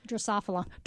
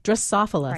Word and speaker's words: Drosophila: The spotted wing Drosophila. – Drosophila.